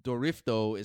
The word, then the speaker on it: Dorifto: His nickname is Dory Dory, like Drift, because Dorifto is how they... pronounce drift in Japanese.